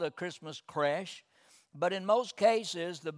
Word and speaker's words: creche: I've never heard of that word, and it's pronounced it's spelled C R E C H E, but it's pronounced creche.